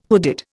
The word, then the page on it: hooded: Indian